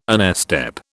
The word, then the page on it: onestep: British English